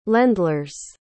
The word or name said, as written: landlers